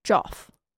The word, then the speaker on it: Geoff: Now, he spells it G-E-O-F-F. – Oh, like Joff. Joffrey. – I think that should be pronounced Geoff. – Joff.